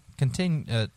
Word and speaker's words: contiguous: And at 12:15, the airspace over the continuous United States is clear of all contiguous. – Contiguous. Yes, I said that correctly.